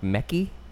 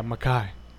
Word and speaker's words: Mekhi: Spelled like Mekki? – You thought his name was Mekki Phifer? – It's M-E-K-H-I. – Yeah, Mekhi. – Okay. Don't look at me like that's how you would pronounce that. Like, that's insane. – It's not M-E-C-K-Y. – It's an I. – It's an H-I. – So that's Mekhi.